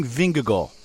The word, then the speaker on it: Vingegaard: Dennis, let's talk about the main man of this Tour de France for you, Jonas Vingegaard. I'll ask you first of all, how do you say his name? We've been saying Vingegaard. – And that's exactly correct.